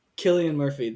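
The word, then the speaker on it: Cillian Murphy: Killian Murphy.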